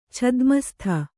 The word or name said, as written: ಛದ್ಮಸ್ಥ